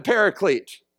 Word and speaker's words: paraclete: We just take that Greek word and we change the pronunciation a little bit because we're better, and we say he's the paraclete, okay?